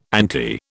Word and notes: ante: British English

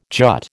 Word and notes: jut: American English

